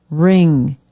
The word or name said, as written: ring